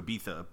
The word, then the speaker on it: Ibiza: It's spelled I-B-I-Z-A, but it's pronounced Ibiza.